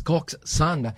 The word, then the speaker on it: coxswain: So, did you know that coxswain pronounced as coxswain, mga kapuwersa?